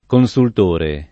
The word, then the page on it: consultore: lingua italiana